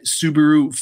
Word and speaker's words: Subaru: How do you pronounce Subar? Subaru. – That's there we go.